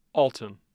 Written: Alton

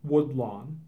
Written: Woodlawn

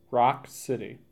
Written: Rock City

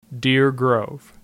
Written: Deer Grove

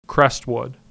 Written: Crestwood

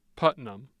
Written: Putnam

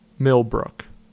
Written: Millbrook

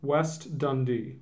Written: West Dundee

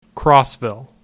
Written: Crossville

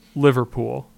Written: Liverpool

